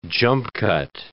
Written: jump-cut

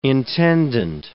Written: intendant

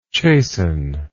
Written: chastenment

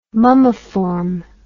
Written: mummiform

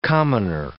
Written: commoner

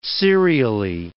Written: serially

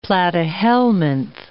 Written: platyhelminth

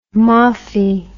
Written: moffie

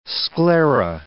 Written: sclera

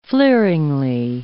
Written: fleeringly

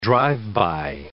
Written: drive-by

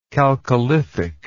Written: chalcolithic

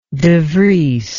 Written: devries

